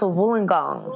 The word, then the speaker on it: Wollongong: It's University of Wollongong? – Close. Oh. Very close. – What is it? – So it's pronounced Wollongong.